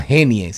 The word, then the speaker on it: Genie: Pero también hay otra que se llama Jenny. Se llama Genie, se escribe e e. G-E-N-I-E. Genie.